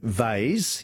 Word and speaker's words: vase: And Robert in Mitcham, David in Adelaide, we say vase, yes, V-A-S-E, rhymes with haze, but we also say Lego for Lego, L-E-G-O.